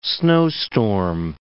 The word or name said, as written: snowstorm